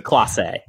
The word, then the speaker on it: Clase: So you look at um, uh, Emmanuel Kloss or Klaas or whatever how you pronounce it. Clase.